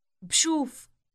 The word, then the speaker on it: بشوف: It begins with a B sound followed immediately with a sh sound. Listen. – بشوف.